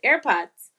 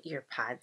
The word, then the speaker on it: AirPods: AirPods? Am I saying it right? Yeah. I always call them earpods, and Liv just. I get mad. – Cringes. – I get mad. she cringes when I say earpods,